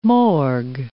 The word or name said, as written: morgue